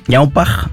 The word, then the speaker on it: Ñaupaj: Ñaupaj, así Ñaupaj, pronunciado francés. Medio francés, pero en realidad es una palabra quechua, mi lengua materna.